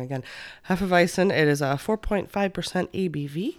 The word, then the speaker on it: Hefeweizen: It's a Hefeweizen.